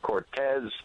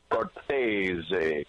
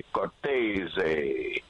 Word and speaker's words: Cortese: Well, like you said, old school Cortez, but I went straight to the horse's mouth one day and I said to my dad, how do we pronounce our last name? He said, Cortese, Cortese.